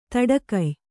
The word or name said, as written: ದಡಿಗೈಯ